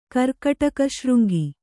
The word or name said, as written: ಕರ್ಕಾಟಕಶೃಂಗಿ